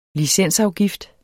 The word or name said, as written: licensafgift